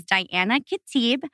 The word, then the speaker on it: Diana Khatib: My name is Diana Khatib, and it's spelled D-I-A-N-A. And my last name is K-H-A-T-I-B.